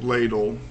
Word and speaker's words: LEDIL: I called it ladle, pronounced after a soup ladle, but it's actually spelled L-E-D-I-L.